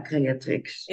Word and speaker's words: creatrix: Wat, wat, wat, wat, wat heb jij geleerd daarover in, de, in het proces van de creatrix. Zeg ik het goed? Ja, creatrix.